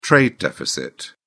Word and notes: trade deficit: British English

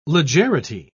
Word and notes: legerity: British English

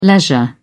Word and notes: leisure: British English

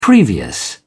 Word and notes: previous: British English

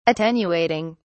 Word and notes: attenuating: British English

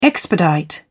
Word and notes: expedite: British English

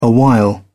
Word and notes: awhile: British English

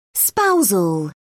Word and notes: spousal: British English

